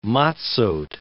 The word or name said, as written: matzoth